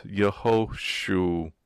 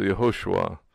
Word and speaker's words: Yehoshua: Nikedot, it would indicate the vowel, but so it's Yehoshua. So Yehoshua, which means Yah or Yahweh, if you're going to pronounce the name of God, that God is salvation.